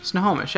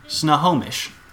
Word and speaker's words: Snohomish: S-O-N. No. – No. – It's S-N-O. – Snohomish. – Snohomish. – Snohomish, yeah. – Snohomish. Great. I don't know what that's how those letters work. – Snohomish.